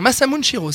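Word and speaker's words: Masamune Shirow: Est-ce que je le prononce bien Masamune Shiro, c'est comme ça Bravo. Ouais.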